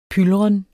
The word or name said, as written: pylren